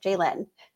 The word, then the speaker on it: JaLynn: And my name is pronounced Jalen. It's for all you that are listening. I always tell everybody, it's just think of Jay Leno. Just take off the O. Jalen.